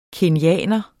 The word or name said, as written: kenyaner